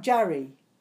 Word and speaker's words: Jari: Jari? – Yeah, that's correct. – Jari.